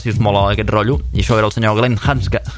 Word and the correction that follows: Glenn Hansard: perdoneu, Glenn Hansard, costa molt pronunciar el seu cognom.